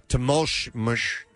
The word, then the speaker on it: Temoxoxtle: How is X pronounced in... – Like an ush. – Ush. Okay. Temulsh. I can't pronounce his name. – Just call him Juan. – T-E-M-O-X-O-X-T-L-E.